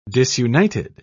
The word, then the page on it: disunited: British English